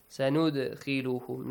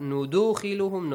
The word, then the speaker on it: سَنُدْخِلُهُمْ: سَنُدْخِلُهُمْ Not نُدُخِلُهُمْ, no. نُدَخِلُهُمْ, no. نُدْ Just an echoing sound.